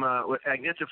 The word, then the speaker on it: Agnetha: I'm very curious about the song you did with Agatha from ABBA. What'd you do with her? – Oh, from Agnetha Faltskog? – Oh, I pronounced it wrong.